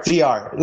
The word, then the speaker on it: Villar: Villar? – Gonzalo Villar? – Sorry. Gonzalo Villar. Sorry, VR. Like, sorry, my bad. I'm saying it wrong. It's VR. Like. – So Villar. – It's like virtual reality.